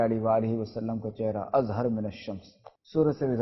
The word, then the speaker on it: اظہر من الشمس: دیکھیں آپ نے یہ پڑھا دوستو یہ اظہرو من الشمس کے تلفظ ہوئی ہے مگر ہمارے کہیں اظہر من الشمس.